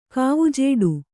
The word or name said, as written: ಕಾವುಜೇಡು